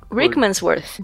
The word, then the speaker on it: Rickmansworth: Aí uma garota sozinha, numa lanchonete, em um lugar quase impronunciável, um Rich, Rick, né? Rickmansworth.